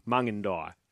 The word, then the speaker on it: Mungindi: Mungindi, sorry. I pronounced it wrong. I think I said Mungindi. Mungindi. Apologies. Thank you for the phonetics on that one.